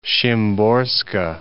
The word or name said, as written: Szymborska